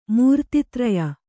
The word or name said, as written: ಮೂರ್ತಿತ್ರಯ